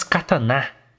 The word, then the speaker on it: katana: Na arte do filme, que é essa que está aqui atrás da gente, Nicolas Cage está com duas katana. É a pronúncia certa, hein? Katana. É na katana, não, seus otaku.